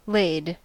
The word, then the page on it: laid: American English